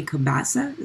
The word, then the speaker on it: Cabassa: Let's move on to Road Rage featuring Jay Cabassa. Is that how you pronounce his last name? – Yeah.